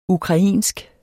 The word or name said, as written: ukrainsk